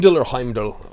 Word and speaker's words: Heimdall: The MCU, Marvel movies, Heidel, you know? I can never pronounce his name. Heimdall, or we'll talk to a Norwegian and they'll tell us we're all wrong.